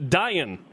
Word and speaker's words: Dayan: It's pronounced Dayan, right? Yeah, it's like Ryan with a D.